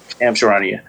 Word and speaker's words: Shams Charania: And one thing that Shams Charania from Yahoo Sports The Athletic said is that that's how you say his name. I never knew. I just be like Sham. – Yeah, Shams Charania.